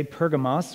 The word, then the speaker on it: Pergamos: But what of Pergamum? Let's bring back our handy map up here. And so we are in, as this map would say, Pergamos, which is an alternative translation and pronunciation for Pergamum.